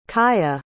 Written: cailleach

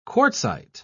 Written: quartzite

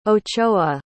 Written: ochoa

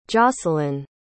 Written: jocelyn

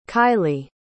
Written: kylee

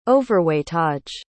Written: overweightage

